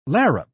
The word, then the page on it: larrup: British English